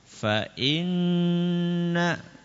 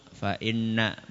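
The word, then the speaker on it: fa inna (فإن): Cara bacanya gimana Ustaz? Agak ditahan. fa inna, ya bukan langsung fa inna, bukan, tapi fa inna, ya kita ulangi, fa inna ma'al usri yusra.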